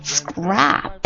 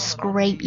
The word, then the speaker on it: scrap: 另外单词它少了一个 e， scrap。 S-C-R-A-P 在写法上是非常相近的，但它读音呢，一个是 scrape，一个是 scrap。 scrap scrap 什么意思呢？ S-C-R-A-P scrap 它表示名词，碎片或者是废弃的零碎的形容词的意思。